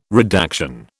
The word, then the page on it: redaction: British English